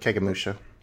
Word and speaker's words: Kagemusha: But got me Akira Kurosawa's, I'm probably going to butcher this, but Kagemusha. – Yep, Kagemusha.